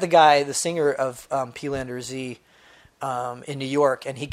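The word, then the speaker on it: Peelander-Z: Peelander Z, yeah.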